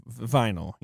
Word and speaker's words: Vinyl: Well, and I'll say, a lot of the crew that worked on the television series Vanille, HBO's Vanille, Uh-huh. Vinyl, he's referring to.